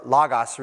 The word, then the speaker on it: logos: In the Greek, it's the phrase is called logos, or logos really is the way it's pronounced, but logos, whatever.